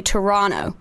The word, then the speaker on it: Toronto: But if you're from Toronto, you have to say Toronto. You don't pronounce the T.